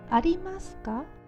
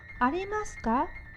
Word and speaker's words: ありますか: 有没有发音比较简单，叫做ありますか？ありまスカ、ありますか？ – ありますか？